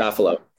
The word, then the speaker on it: Safilo: is it Safilo Group? Is that how you say it? – Safilo. – Safilo Group. Okay.